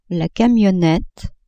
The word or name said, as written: camionnette